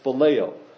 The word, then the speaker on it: phileo: Kind of looks like Phil and Leo, but it's pronounced phileo.